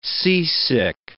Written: seasick

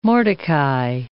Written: Mordecai

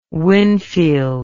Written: winfield

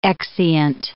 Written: exeunt

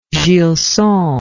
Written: Gilson

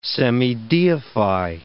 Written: semideify

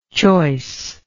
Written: choiceness

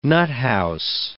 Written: nuthouse

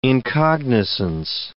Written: incognizance

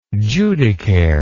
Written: judicare

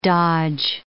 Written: dodge

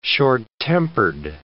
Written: short-tempered

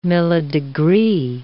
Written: millidegree

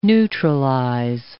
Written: neutralize

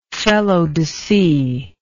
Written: felodese

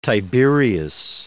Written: Tiberius

